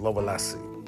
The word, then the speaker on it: Lovelace: se llama eh, La historia de Linda Lovelace, Lovelace, se escribe Love Lace, Lovelace. Murió, por cierto, el 22 de abril de, 2022, de 2002.